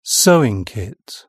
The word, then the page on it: sewing kit: British English